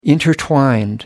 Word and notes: intertwined: British English

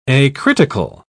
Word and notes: acritical: British English

